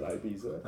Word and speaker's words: Ibiza: He said Ibiza.